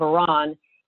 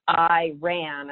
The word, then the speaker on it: Iran: And, you know, what I remember even now, decades later, was a big sign with a picture that said the pronunciation of Iran is not Iran, that had it and was demonstrated with a picture of an eye and a picture of a person running so that we would never forget about that.